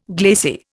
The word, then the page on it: glacier: Indian